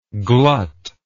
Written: gluttingly

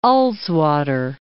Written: Ullswater

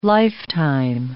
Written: lifetime

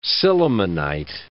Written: sillimanite

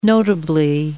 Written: notably

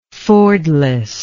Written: fordless

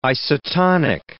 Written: isotonic

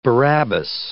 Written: Barabbas

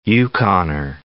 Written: Yukoner

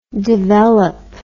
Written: develope

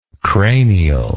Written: cranially

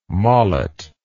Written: molet

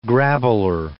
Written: grabbler

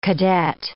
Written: cadet

